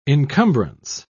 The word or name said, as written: encumbrance